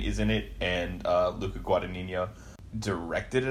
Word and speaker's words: Guadagnino: Your boy, we talked about him a lot on the, or his films at least a lot on the Oscar podcast, Guadagnino, or however you say his name. – Luca Guadagnino.